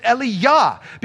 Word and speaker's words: Eliyah: Elijah, he didn't pronounce it Elijah, he pronounced it Eliyah, because Yah was the first word from God's name, Yahweh. It's everywhere in the Old Testament. Anytime you see J-A-H or Y-A-H